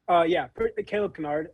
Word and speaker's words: Caleb Kynard: Calebkynard. Like, Calebkynard.